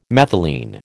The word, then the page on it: methylene: American English